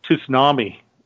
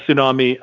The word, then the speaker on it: tsunami: like a tsunami? And I understand from listening to they're supposed to pronounce it T, which apparently, according to George Takei.